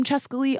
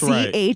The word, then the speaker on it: Chescaleigh: And I'm Chescaleigh all over social media.